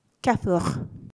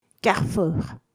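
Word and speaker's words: Carrefour: で、このカルフール、えー、この最初のル、最後のル、両方ともエアです。ですので、フランス語の発音ですと、キャフー、キャフー。になります。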